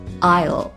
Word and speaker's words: I'll: I'll 是 I 和 will 的缩略形式，注意发音 I'll 结尾的时候要把，对了，舌尖放到上齿的背后顶住 I'll 很好。